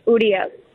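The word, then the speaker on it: Urías: U-R-I-A-S, and the accent is on the I's, so it's Urias. It's almost like it sounds like an E.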